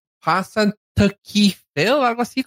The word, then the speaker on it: Punxsutawney: Punxsutawney.